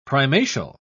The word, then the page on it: primatial: British English